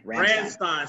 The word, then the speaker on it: Ramstein: Ramstein? – Ramstein.